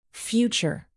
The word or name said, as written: future